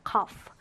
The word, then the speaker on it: cough: cough. 자, cough는 이렇게 gh로 끝나는 단어인데요. 이 gh를 어떻게 발음한다고요? f로 발음해요. 그래서 cough가 되죠.